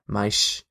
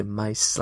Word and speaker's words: mais: In terms of like basic root pronunciation, again, for example, the S in Portuguese from Portugal is a sh sound, mais, whereas in most of Brazil, it's a s sound, so mais, like that.